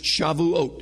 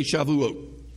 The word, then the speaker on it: Shavuot: It's pronounced Shavuot. Everybody say Shavuot.